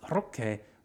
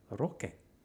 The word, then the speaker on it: Roque: Not Roque, Roque. There'll be no more chorizo rolls for you unless you say it properly, okay?